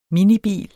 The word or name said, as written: minibil